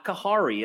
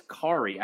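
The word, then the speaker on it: Khari: Whenever we talked about Kari Willis, they wanted to tell you, Derek, that the H is silent. So it's not Kahari, it's Kari.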